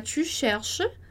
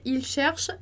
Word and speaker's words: cherche: Para você observar que a gente não pronuncia nem o S do tu, então a gente vai falar tu cherche, e nem o NT do il, elle no plural. Então a gente vai dizer il cherche, elle cherche. E aí a pronúncia vai ficar a mesma para todas essas pessoas que estão aí listadas.